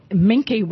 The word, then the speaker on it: Minke: Minke whales is how you pronounce it.